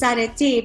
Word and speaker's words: Areti: So I heard of this amazing name called Areti. Am I pronouncing it correctly? – You are. It's actually, in Greek it's Areti, but Areti is an ancient Greek mythology goddess.